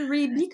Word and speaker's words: Reebee: T'as dit Reby? comment ça s'écrit? Ouais, ça s'écrit R-E-E-B-E-E.